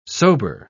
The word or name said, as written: sober